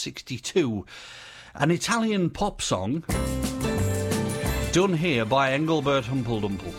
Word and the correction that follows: Engelbert Humperdinck: Engelbert Humperdinck. Engelbert, oh, I always get his name wrong.